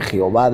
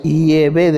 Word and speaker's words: Yahvé: En las diferentes versiones bíblicas que existen, aparece con el nombre de Jehová de los ejércitos y en otras como Yahvé de los ejércitos. Sin embargo, parece ser que la pronunciación más correcta en hebreo arcaico es Yahvé de los ejércitos.